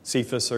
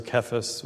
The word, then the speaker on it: Cephas: You are the rock, which is what Cephas or Kephas, whatever you want to pronounce it, what it means.